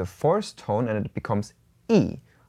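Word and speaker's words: yi (一): Okay, so one is normally pronounced as yi when we only talk about the number one.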